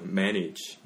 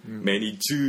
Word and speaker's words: manage: 그래서 원어민 발음 들어보시면 우리도 지으로 끝나요. manage. 음. Can you manage this affair? 우리도 manage 이라고 말해요. 음. 그래서 물론 너무 크고 길게 하면은 그것도 이상해요. – 매니즈 음.